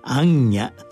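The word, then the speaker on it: 行脚: 総統集心の電話今週は「安家」と題して茨城県安全寺染谷天秀さんのお話です行うの行にカメラの三脚の脚をつなげて「安家」と読みます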